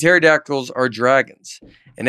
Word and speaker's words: pterodactyls: Dusty very clearly said, I think pedactricals. Oh, pterodactyls. – There's another silent P in there.